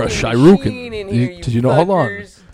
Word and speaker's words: shuriken: Shuriken, I would pronounce that.